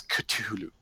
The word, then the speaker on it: Cthulhu: Now, the proper pronunciation is is Cthulhu. But anyone who does that all the time is someone you don't want to know.